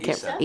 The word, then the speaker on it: Issa: I, Isa Rae? Is it Isa? – I'm Issa. Issa.